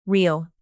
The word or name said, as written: reel